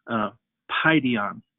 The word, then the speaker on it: Paidion: You see, the word children comes from the Greek word Paidion, that's how you say it, P-A-I-D-I-O-N, Paidion.